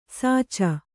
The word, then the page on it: ಸಾಚ: sāca